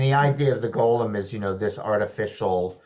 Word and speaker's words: golem: In addition to photographing ghosts, which you mentioned, you talk about the legend of the golem and history of automata, if I'm saying that right. – Yeah, golem. Yep.